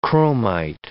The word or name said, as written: chromite